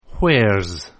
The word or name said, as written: where's